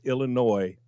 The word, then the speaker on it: Illinois: I will start pronouncing the state of Illinois, even though it plainly has an S on the end. I'm not the one that put the S there. I mean, who goes, the S is silent? Well, then don't put it on there. But it's Illinois from now on.